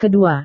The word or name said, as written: kedua